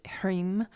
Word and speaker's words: Hrim: So today's mantra is Om Harim, which is, and Harim, you probably know Om is spelled O-M, and Harim is spelled H-R-I-M, pronounced Harim.